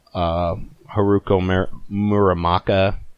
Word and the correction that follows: Haruki Murakami: Haruki Murakami.